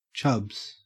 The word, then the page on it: chubs: Australian English